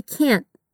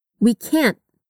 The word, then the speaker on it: can't: Note que o T desse can't é pronunciado de forma muito sutil. Repete. We can't. We can't. É como se você posicionasse a língua, mas não chegasse a completar o som.